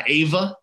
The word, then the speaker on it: Ava: I saw Ava, which is how you pronounce that movie.